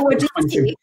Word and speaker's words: Wojcicki: Esther Wojcicki.